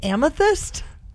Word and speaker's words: amethyst: Are you trying to say amethyst? – Yeah.